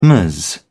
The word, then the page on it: ms: British English